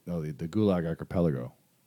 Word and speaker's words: Gulag Archipelago: How do you pronounce that? Gulag Archipelago? – The Gulag Archipelago. – Gulag Archipelago. Yeah, I butchered that, but I mean, that example is mind-blowing.